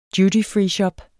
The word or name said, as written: dutyfreeshop